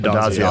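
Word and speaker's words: Addazio: Azado? How do you say it? Adazio. – Adazio.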